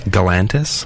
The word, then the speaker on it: Galantis: How do you say it? – Galantis. – Galantis.